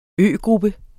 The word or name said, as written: øgruppe